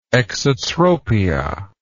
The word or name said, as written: exotropia